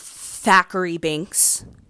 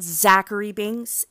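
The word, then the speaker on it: Thackery Binx: For all out there who do not know the Pernect, oh my God, Pernect, the correct, I can't talk, I'm sorry, the correct pronunciation, it's Thackeray Binks, It is not Zachary Binks. It is Thackeray Binks. That, let me just go on record and say, once again, it is not Zachary Binks. So get it right, peeps.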